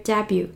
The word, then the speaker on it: debut: 那么二十三只 giant panda cubs 就是小熊猫，小熊猫，born in 2016 就是在二零一六年今年出生的小熊猫们，made their debut，made their debut。好，debut 这个词，d e b u t，d e b u t，注意 t 不发音。